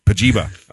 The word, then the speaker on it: Pajiba: Pajiba. – Oh. – Is that how you pronounce it? – Pajiba. It's supposed to rhyme with vagina.